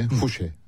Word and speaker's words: Fouché: Fouché, yani Fransızlar Fouché diye doğrudan telaffuz ediyor.